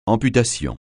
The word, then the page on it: amputation: francais